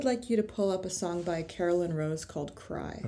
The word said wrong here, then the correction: Caroline: Caroline Rose. – C-A-R-O-L-I-N-E? – Yes. The way you're supposed to pronounce Caroline. – And what was it called again, the song? – Cry, C-R-Y.